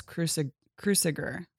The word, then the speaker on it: Cruciger: That is a symbolic object called a Globus Cruciger, C-R-U-C-I-G-E-R, Cruciger. I hope that I'm pronouncing it. It's Latin. Thanks. – Let me see. Great. That symbolic object is called a Globus Cruciger. Cruciger. Right? – Yeah, I think you're right. – That sounds Cruciger? Right to me. – Take that. She struggled as long right? – with me. – You said it right.